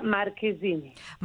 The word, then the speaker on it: Marchesini: Federica Marchesini. – Marchesini. I've been saying Marchesini. I'm so sorry. – Yes. – Federica Marchesini. – Yes, the CH in Italian is a K, like Colcaccio.